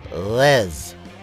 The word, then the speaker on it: Reims: but you actually pronounce it Les. Les. Like that, I think. Les. Les. Les. Anyway, spell R-E-I-M-S.